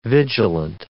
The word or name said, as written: vigilant